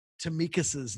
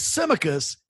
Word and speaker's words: Tsimikas: Tsimikas' name correctly. So when he signed, I listened to as many Greek people as possible pronounce the name. Tsimikas seems to be the way